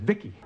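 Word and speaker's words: VICI: V-I-C-I, I pronounce it Vicki.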